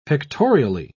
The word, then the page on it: pictorially: British English